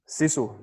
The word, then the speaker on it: Sisu: Sisu, yeah.